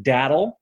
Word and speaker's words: datil: People there pronounce it Datil, so we do the same.